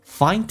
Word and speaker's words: find: You cannot find it. Find. 双元音 I 再饱满一点，加个鼻音 find，加个 it 有一个连音叫 find， it 的 t 去掉。